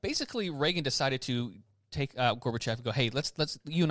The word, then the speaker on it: Gorbachev: So back in the 80s when President Reagan was in office and Gorbachev, is that how you say his name? – Gorbachev.